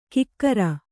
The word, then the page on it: ಕಿಕ್ಕಿರಿ: kikkiri